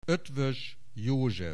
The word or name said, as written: Eötvös, József